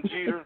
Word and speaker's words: Jeter: It's Taz Tim Jeter. But hey, don't call me Taz, but you can read it as Taz. It's Taz Tim Jeter. Jeter is spelled J-E-T-E-R, like the baseball player.